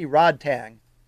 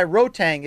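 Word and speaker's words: Rodtang: because when I see it, I see Rod Tang. But this guy, Rotang, is an absolute beast of a brawler, fighter, warrior, Muay Thai machine.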